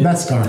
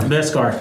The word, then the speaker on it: Beskar: It's Eskar or something. – Beskar. – Beskar. Thank you.